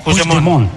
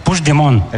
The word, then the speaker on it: Puigdemont: Me has preguntado antes, ¿cómo se dice este nombre? – Sí, voy a intentar. – Puigdemont. – Puigdemont. – Vale. – Puigdemont. – Eso es. – Sí, lo dices como francés, así.